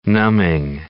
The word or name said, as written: numbing